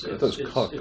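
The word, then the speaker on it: Koch: I believe it's Coke. It's Coke.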